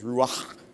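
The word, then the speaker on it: ruach (רוח): It's ruach, but the k at the end should be kind of a h. There's ruach. And I can't do it right, so I'll just stick with ruach.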